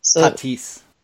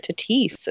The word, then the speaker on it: Tatis: It's pronounced Tatis. – Is it really? – Yep, it is. Thank you. – We're both learning like last name lessons here. Tatis.